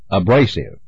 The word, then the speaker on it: abrasive: The second syllable, pair, P-A-R, is correct. – Surfaces. – The first syllable, sir, S-U-R, is correct. – abrasive. – The second syllable, bra, B-R-A, is correct. – Frequently. – The first syllable, free, F-R-E, is correct.